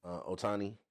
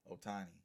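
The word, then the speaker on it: Ohtani: Otani. – Otani.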